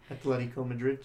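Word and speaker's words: Atlético Madrid: and that's Atletico Madrid, if I'm saying that correctly. – Atletico Madrid, yeah.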